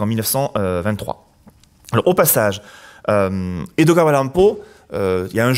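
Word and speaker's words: Edogawa Rampo: Si on prononce Edgar Allan Poe en japonais, d'abord c'est comme ça, Edoga Allan Et quand on le prononce, c'est Edoga Allan Si on mix, en tout cas si on découpe autrement, ça devient Edogawa Lampo.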